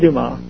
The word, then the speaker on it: DeMar: Do you call it DeMar or DeMoss? – I pronounce it DeMar. – Okay, DeMar. – Actually, everybody else in my family, including my Uncle Bob, says Dumas, but I go to the traditional French.